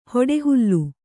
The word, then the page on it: ಹೊಡೆಹುಲ್ಲು: hoḍe hullu